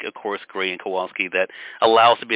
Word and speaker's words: Kowalski: Kowalski, correct.